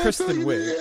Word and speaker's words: Kristen Wiig: It's spelled Kristen. So that's Kristen. – Right. Kristen Wiig. – Yeah. That's Kristen Wiig.